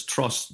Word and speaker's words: Truss: And when you have people like Liz Truss, which rhymes with distrust, I mean, you know, come on, it's even simpler.